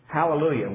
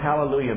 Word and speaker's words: Hallelujah: Hallelujah. We pronounce it in English with an H. Now that H is actually should be silent, and some people make an issue about that.